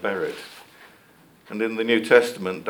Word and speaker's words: berith: Well, the Hebrew word for covenant is berith. I'm sure Brian will tell me if I'm saying that wrong. No, berith, okay. Berit. Yeah, okay.